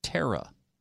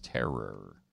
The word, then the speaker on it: terror: I would really like it for somebody to tell these Easterners there is no A in the word terror. it is not pronounced terra. It is terror.